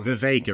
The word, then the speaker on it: Vivek: and Vivek, it rhymes with cake.